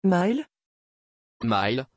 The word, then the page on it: Maile: French